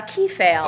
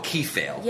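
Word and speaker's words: kephalē: So for next time, I hope to pick up on, you guys pronounce it Kephal? – Kephal. – Oh, Kephal. – Yes. – Kephal, the Greek word for head.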